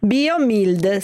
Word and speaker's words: BioMild: BioMild, scritto come si pronuncia.